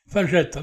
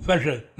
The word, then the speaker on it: Fajet: Fagette, hein, quelquefois on me dit, mais comment ça s'écrit?